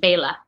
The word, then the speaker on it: Bela: So B-A-Y-L-A. I guess it is. Yeah. – Yeah, it's like Bay or like B-A-Y. Yeah, Bay and then L-U-H. Bela.